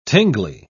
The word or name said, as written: tingly